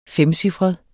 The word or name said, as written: femcifret